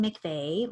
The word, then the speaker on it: McVea: Well, I'm across all social media platforms as Susan McVeigh.